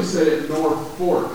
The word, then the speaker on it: Norfolk: They actually say it Norfolk, which is kind of funny because it's not spelled like that at all.